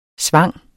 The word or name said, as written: svang